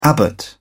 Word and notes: abbot: British English

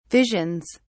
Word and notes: visions: British English

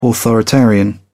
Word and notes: authoritarian: British English

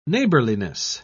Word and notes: neighbourliness: British English

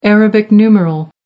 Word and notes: arabic numeral: American English

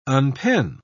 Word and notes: unpen: British English

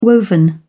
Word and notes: woven: British English

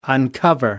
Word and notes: uncover: American English

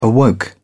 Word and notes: awoke: British English

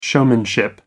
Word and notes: showmanship: British English

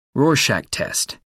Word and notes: rorschach test: American English

